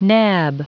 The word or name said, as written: nab